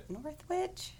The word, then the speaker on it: Northwich: How would I say that? – I think that's right. – Northwich? It's North and then W-I-C-H.